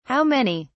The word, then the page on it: how many: British English